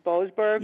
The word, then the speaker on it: Boasberg: Yeah, the judge is James, I think his last name is pronounced Boasberg.